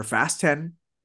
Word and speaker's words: Fast X: Fast X or Fast 10 is how it's actually pronounced, is here.